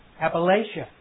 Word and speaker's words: Appalachia: Is that right? Appalachia. There's a battle going on down there about how to pronounce it. But Appalachia.